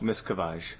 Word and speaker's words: Miscavige: Miscavige? – Miscavige.